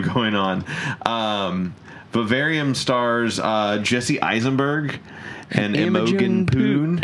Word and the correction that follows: Poots: Imogen Poots.